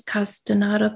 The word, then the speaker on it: Castaneda: Like, was it the Carlos Castaneda books? – Uh-huh.